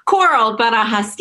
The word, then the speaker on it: Coral Bajas: Coral Bajas, yes.